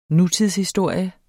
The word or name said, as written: nutidshistorie